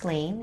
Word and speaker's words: Lane: It's spelled Akira, as A-K-I-R-A. My last name is Lane, L-A-N-E. And, you know, when it comes out, I hope you guys can have the chance to watch it and have a great laugh.